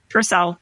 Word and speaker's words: Purcell: Who's Purcell? Parcell? – William Purcell? Yeah. What Purcell. – Purcell? – Purcell. Yes. Okay.